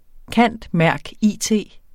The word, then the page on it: cand.merc.it.: ˈkanˀdˈmæɐ̯gˈiˀˌteˀ